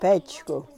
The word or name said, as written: pechku